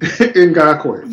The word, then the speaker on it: Ngakoue: Yannick Ngakoue I can't pronounce his last name. – Ngakoue.